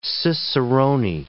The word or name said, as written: cicerone